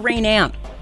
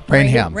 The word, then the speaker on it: Rainham: Is it Rainham or Rainam? – Rainham. – Rainham. – You pronounce the H.